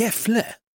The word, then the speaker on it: Gefle: Jag vet att det fanns en festival som hette Gefle. – Ja, Gefle Metal. – Gefle, det kanske du sa, ja. – Ja. Fast jag uttalar Gävle Metal, men Gefle.